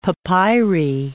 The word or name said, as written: papyri